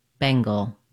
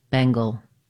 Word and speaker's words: Bengal: To pronounce Bengal, as in Bengal tiger, you need first syllable stress. Bang is long and gul is short. Letter N says the NG or ng sound. Bang, Bengal. Letter A is silent. Go right from the G to the L. Gul. Bengal.